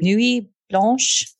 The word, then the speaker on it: Nuit blanche: No, uh, how do you pronounce that first word? Nuit, Nuit blanche. Nuit blanche.